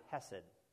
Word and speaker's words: hesed: And that's kind of an English way to say it, because to say it properly, you kind of have to cough a little something up. Hesed is how they really say it.